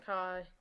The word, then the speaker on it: Qashqai: Qashqai, is that how you say it? I don't really know. It's an Qashqai. SUV. Yeah, Qashqai. – Qashqai, that's it.